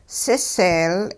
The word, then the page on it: siseel: hupa